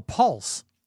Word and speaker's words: pulse: All right, say P-U-L-S-E. Pulse. – Paul. Say Paul. Say Paul. Paul. And Paul. No, not pa. Say Paul and pronounce the L.